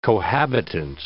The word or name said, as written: cohabitant